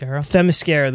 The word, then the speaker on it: Themyscira: The way that, how do you pronounce that again? – Themyscira.